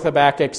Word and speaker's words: Habakkuk: This is one of those books that's a little hard to know how to pronounce, but you'll hear both. But I go with Habakkuk, so that's what we're going with today, okay?